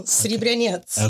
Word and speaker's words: Sribryanets: You did well, Sribryanets.